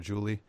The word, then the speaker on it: Julie: But they call her Julie. – Yeah. – Julie. – And that's how it's pronounced in Spanish.